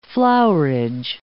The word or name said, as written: flowerage